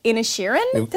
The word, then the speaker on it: Inisherin: But there's The Banshees of Inisherin.